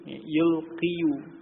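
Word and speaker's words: يُلْقِي: Asalnya yulqiyu, yulqiyu. Tetapi dhammah pada huruf ya itu ditakdirkan karena sulit diucapkan menurut lidah Arab, sehingga menjadi yulqi. Ya, dibaca panjang ya, dengan apa, dalam bacaan dengan sukun, yulqi.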